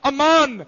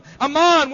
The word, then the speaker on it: aman: That little word believed, I was looking at it again this morning, that little word believed is the Hebrew word, and you spell it like this, A, apostrophe, A-M-A-N, Amon, Amon, where we get our English word, amen.